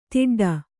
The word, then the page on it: ತಟ್ಟುಡು: taṭṭuḍu